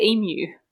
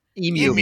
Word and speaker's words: emu: And then the emus came. – Don't even get me started on the pronunciation of that particular bird, because we say emu. – Emu. – Emu.